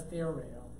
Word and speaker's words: theoreo: But the one I'm talking about is theoreo. Whoops, not in Greek, English. Theoreo. That's a long O on it, too.